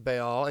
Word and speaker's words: Baal: And so when you go to the mall, which rhymes with Baal, and you see free gift wrapping.